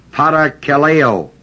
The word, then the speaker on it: parakaleo: The word exhort is parakaleo, P-A-R-A-K-A-L-E,